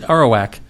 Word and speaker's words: Arawak: I thought it was Arawak. – That's it. Arawak. – Okay. – That's how it's pronounced.